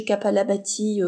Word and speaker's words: Kapalabhati: Alors ça s'écrit, il y a un H, je crois, mais vous cherchez Kapalabhati euh, comme ça se prononce avec un K, et vous trouverez des, vidéos avec des, des, professeurs de yoga qui vous montrent comment pratiquer.